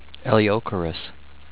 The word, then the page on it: Eleocharis: el-ee-oh-CAIR-iss